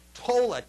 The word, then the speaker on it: towla: This is only the third time this particular word, towla, T-O-W-L-A, that's how we would pronounce the Hebrew word, and our towla.